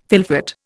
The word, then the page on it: fylfot: Indian